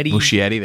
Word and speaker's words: Muschietti: Andy Muscinetti. Is that how you say his name? – Muschietti.